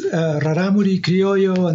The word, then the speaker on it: Rarámuri Criollo: Yeah, so that is Rarámuri Criollo, and they are a biotype of Criollo cattle from the Copper Canyon in Chihuahua, Mexico.